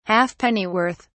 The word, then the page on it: halfpennyworth: British English